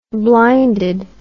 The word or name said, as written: blinded